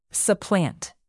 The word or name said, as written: supplant